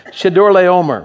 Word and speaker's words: Chedorlaomer: All right, here's how I would pronounce it. Chedorlaomer. Everybody say Chedorlaomer. Close enough for the letters I have here.